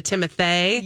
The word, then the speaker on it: Timothée: I like to say his name like that because the two E's makes it a Timothée.